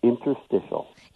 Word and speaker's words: interstitial: Am I saying that right? I know you tried helping me out with how to pronounce this word. Because when people get bad news and they hear something new, how do I pronounce this? – It's interstitial.